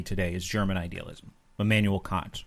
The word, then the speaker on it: Kant: Kant. That's how it's pronounced, really.